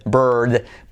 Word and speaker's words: bird: Se escribe bird, pero se dice bird, bird.